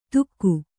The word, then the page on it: ತುಕ್ಕು: tukku